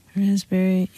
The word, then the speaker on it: raspberry: K-N-E-E-L, Neil. – Raspberry, answer. – Silent right. letters. – Yes, silent letters. Right. Raspberry is the silent S. – P. – Well, P. Sorry.